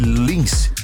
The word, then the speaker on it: LNNCE: Escreve-se dessa maneira, mas pronuncia-se Lins.